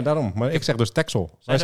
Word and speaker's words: Texel: Ik spreek het ook uit als Bruxelles, net als Texel. – Texel. Ja, daar ben ik het mee eens. – Ik heb nog nooit een X als een X uitgesproken. Jij? Of was een S bedoel ik, Texel.